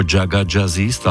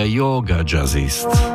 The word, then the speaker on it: Jaga Jazzist: Im vôbec nevadí, keď to niekto vyslovuje ako Jaga Jazzist, ale oni samotní si hovoria Yoga Jazzist.